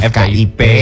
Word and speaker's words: FKIP: FKIP atau FKIP nih bacanya. – FKIP. Ya.